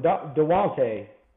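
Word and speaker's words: Daunte: Your final season, you were pretty much the mentor for Dante Culpepper. – You know, for a lot more than DeWante, I mean, that's phonetic pronunciation, of course.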